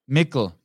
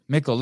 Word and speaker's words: Mikkel: Yeah, kind of like not nickel, but Mikkel. Mikkel. – Mikkel. Mikkel. Like pickle. Pickle, nickel, Mikkel. – Yeah, exactly. – Mikkel.